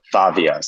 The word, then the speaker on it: Favia: Did you say Fabia? How do you spell it? – Fabia. So F-A-V-I-A.